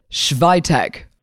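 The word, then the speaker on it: Świątek: Say it with me. Iga. – Iga. – Schweitek. – Schweitech. – Wow, hey, Iga Schweitech.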